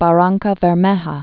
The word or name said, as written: Barrancabermeja